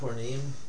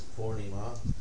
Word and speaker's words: Purnima: Oh, by the way, one word of pronunciation, it's not Purnim, it's actually Purnima. devotees get, there are three vowels in the word, and devotees get all three of them wrong, which is admirable.